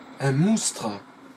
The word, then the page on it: moustre: France (Avignon); [ˈmustʁə]